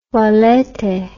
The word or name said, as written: valete